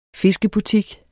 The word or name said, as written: fiskebutik